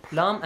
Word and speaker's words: لا: That shape is pronounced as la.